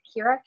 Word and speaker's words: Kira: It's Kira, K-I-R-A, and last name Day, D-A-Y.